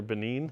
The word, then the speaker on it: Benin: And you went to, is it pronounced Benin or Benin? – Both, either one.